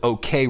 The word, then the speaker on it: OKeh: Spell it O-K-E-H. It's a conglomeration, a portmanteau of the two people that owned it. And some people don't pronounce it OK Records. They say OK. – Okay. – Because that's O-K-E-H.